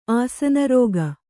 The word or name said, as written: ಆಸನರೋಗ